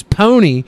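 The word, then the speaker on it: Pwnie: The name the Pony Awards, pronounced as pony, is meant to sound like the Tony Awards.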